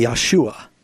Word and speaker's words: Yeshua: If you were going to say Jesus' name in Hebrew, it would be Yeshua.